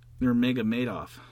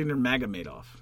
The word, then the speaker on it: Nurmagomedov: How do you pronounce his last name? – Nurmagomedov. – Yeah. Okay, I'm with you. So far, you and I are tracking in the same way. – It's actually Nurmagomedov.